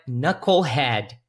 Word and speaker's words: knucklehead: Lembra só que knuckle é como a palavra knife, que você não vai pronunciar esse K aqui no início, ok? Então é knucklehead.